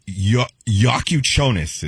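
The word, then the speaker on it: Jakučionis: Kel-El Ware, and then the guard, Yakuchonis is how you say his last name.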